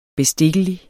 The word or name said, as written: bestikkelig